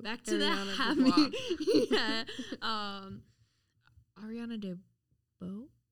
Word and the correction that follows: DeBose: I looked up a video on how to pronounce this according to herself. Let's watch. – My name is Ariana DeBose.